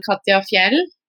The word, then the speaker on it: Katja Fjeld: Actually, you pronounce my name quite well. I'm Katya Fjell.